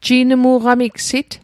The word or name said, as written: ji'nmu'qamigsit